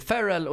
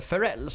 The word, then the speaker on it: Ferrell: Till exempel Will Ferrell. Som faktiskt heter då Will Ferrell och inte Ferrell som vi lärde oss när vi eh, gjorde eh, alltså researchen till Night at Roxbury.